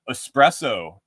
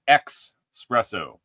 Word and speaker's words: espresso: Espresso, it's not espresso. When you talk about the little cup, the little hot concentrated shot, it's espresso. It's spelled E-S-P-R-E-S-S-O. Man, don't ax your mother. Just keep the X out of espresso.